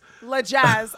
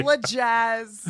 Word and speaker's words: Lajas: I really appreciate you pronounced it right. – What was I going to say? Lajas? – Lajas. I've had it. – Have you really? – Lajas. Yeah. So I appreciate that. – L-A-J-A-S? – Yeah, it's a mess.